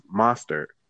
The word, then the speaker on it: Mostert: I'm going to butcher his name. It's Mostert.